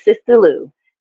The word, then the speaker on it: Sista Lu: My name is Sister Lou. That is spelled S-I-S-T-A-L-U.